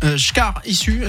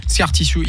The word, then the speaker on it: Scar Tissue: Scar Tissue. Est-ce que je le prononce correctement? – Pas du tout. – Non, d'accord, j'étais sûr. – Scar Tissue.